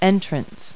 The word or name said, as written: entrance